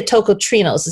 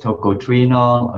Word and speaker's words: tocotrienol: And before we get into maybe your interests in the nutrient, maybe tell us actually what toco, now I say a tocotrienols. Is that the right way to say it, or is it tocotrienols? – People have said it both ways, tocotrienol or tocotrienol. I usually use the latter, tocotrienol.